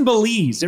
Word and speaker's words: Belize: You were just in Belize. It rhymes with cheese.